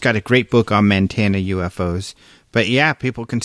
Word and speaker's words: Montana: Montana. That's how they say it out there.